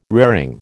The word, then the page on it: raring: American English